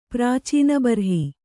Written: ಪ್ರಾಚೀನಬರ್ಹಿ

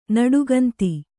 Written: ನುಡಿಗುಂದು

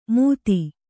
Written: ಮೂತಿ

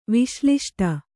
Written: ವಿಶ್ಲಿಷ್ಟ

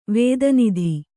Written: ವೇದನಿಧಿ

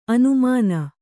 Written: ಆನೆಮೀನು

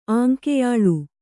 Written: ಆಂಕೆಯಾಳ್

